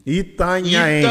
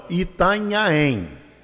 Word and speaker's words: Itanhaém: Qual é a pronúncia correta? – Itanhaém. Itanhaém. Ita pedra nhaém sonora, o que reverbera. – Então, Itanhaém. Isso. É, eu sempre tive dificuldade. Eu falo Itanhaém. Tá errado, né? Não. Itanhaém.